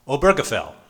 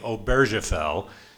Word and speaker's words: Obergefell: How would you pronounce it in German? Obergefell. Okay. I was a French-speaking missionary, so I'm inclined to say Obergefell.